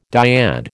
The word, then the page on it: dyad: American English